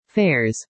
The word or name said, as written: fares